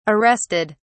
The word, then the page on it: arrested: British English